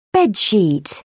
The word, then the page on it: bed sheet: British English